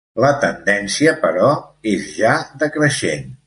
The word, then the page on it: decreixent: Catalan